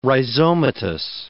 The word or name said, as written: rhizomatous